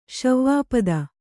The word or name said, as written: ಶಿವಪದ